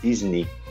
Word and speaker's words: Disney: O, a, a, o lugar tão visitado aí por todos os brasileiros, todo mundo gosta muito de ir, a Disney. Na verdade, em inglês a pronúncia é Disney.